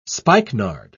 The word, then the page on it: spikenard: British English